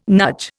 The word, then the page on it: nudge: Indian